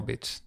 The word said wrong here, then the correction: beach: det lät bara som det. – Ah, okay, du sa, ja, du sa bitch. – Beach. – Så beach, absolut. Jag uttalade det så dåligt bara.